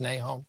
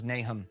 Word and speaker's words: Nahom: Is it pronounced Nahom? – Yeah. I mean, you can. We don't know how it was pronounced anciently. – Okay. – The pronunciation guide in the Book of Mormon says Nahom.